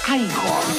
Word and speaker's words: Igor: Usted debe de ser Igor. No, se pronuncia Igor.